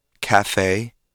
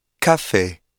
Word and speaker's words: café: Listen carefully to the English pronunciation of the words café and entrée. Can you hear how the final vowel is drawn out into two different sounds? In French, however, each vowel is pronounced with a single pure sound.